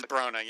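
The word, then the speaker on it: Bronagh: Brona Gallagher? Bernie McLaughlin. – I think it is Brona, yeah. That's right.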